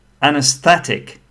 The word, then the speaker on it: anesthetic: Again, anesthetic, four syllables. Sta is stressed.